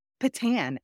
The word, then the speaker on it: Patin: I am joined by Chicagoan Nikki, is it Patton? – Payton? It's Patan, actually. – Patan, okay. – Yeah, Creole name.